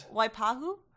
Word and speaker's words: Waipahu: Waipahu? – I think you crushed it.